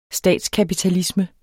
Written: statskapitalisme

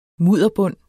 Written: mudderbund

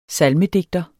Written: SALMEDIGTERNES

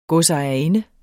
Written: godsejerinde

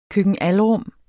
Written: køkken-alrum

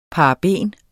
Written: paraben

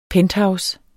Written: penthouse

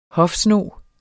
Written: hofsnog

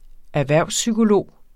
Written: erhvervspsykolog

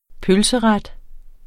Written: pølseret

